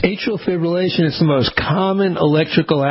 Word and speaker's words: atrial fibrillation: on atrial fibrillation. You know, I don't think I ever pronounced this correctly. I'm a big one on R's. It's actually atrial fibrillation.